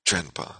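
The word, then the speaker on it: drenpa (དྲན་པ): That's pronounced drenpa, like a T, like as if it's T-R-E-N-P-A.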